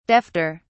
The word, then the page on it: defter: British English